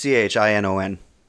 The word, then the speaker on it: Chinon: Not spelled like the way you might wear your hair, ladies. There's no G in this Chinon. – There is no G in this Chinon. This is Chinon.